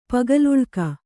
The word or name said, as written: ಪಗಲುಳ್ಕ